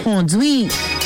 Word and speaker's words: kondwit: And your Creole word for today is the word conduit. Conduit. It is spelled K-O-N-D-W-I-T, no accents.